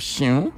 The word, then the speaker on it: Siun: It's pronounced Siun. – SIUN. – If you're Shion. From Ogun State, Shion.